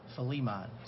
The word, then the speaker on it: Philemon: The best way I can think of to remember is take the word Philly and just take the emphasis on the Lee part, Philemon.